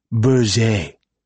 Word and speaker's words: Berger: The defense attorney doesn't pronounce his name like what you get at P.J. Clark's. He pronounces it Berger and insists that you do too, which Malone steadfastly refuses to do, except in open court so he doesn't look like a smartass in front of the judge.